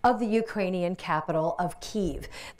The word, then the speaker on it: Kyiv: The common pronunciation used to be Kiev, but that's based on the Russian pronunciation of that city.